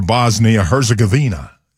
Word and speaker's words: Bosnia-Herzegovina: was able to withstand that and win 2-0 over Bosnia-Herzegovina.